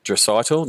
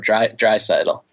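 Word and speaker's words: Draisaitl: Dreisaitl, no. I haven't done it right, have I? A little bit more emphasis on the first part in addition to the last part, Dreisaitl.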